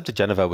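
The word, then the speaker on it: diGenova: Yeah, Joseph DeGeneva.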